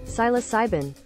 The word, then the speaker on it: psilocybin: Psilocybin.